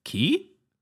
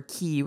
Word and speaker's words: Qui: But if you look at the music video for Key, which is spelled Q-U-I ...